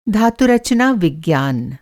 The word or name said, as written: धातुरचना-विज्ञान